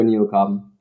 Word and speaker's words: Neocarbon: Oder sagt man Neocarbon? Nur Neocarbon. Okay.